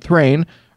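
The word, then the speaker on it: Thráin: He escaped and took his son Thrine, rhymes with fine, and grandson Thorin with him.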